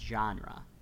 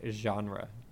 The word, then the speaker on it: genre: I like that you pronounce a hard G when you say the word genre.